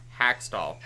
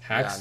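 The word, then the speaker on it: Hakstol: How do you say this name? Dave Haxtell? – Haxtell. – Haxtell. – Not to be confused with Hextall.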